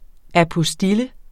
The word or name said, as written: apostille